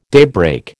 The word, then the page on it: daybreak: American English